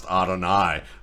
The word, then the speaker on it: Adonai: And really, I mean, a great name just in the sense that it's the English spelling for the Hebrew word for God, actually pronounced Adonai,